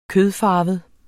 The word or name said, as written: kødfarvet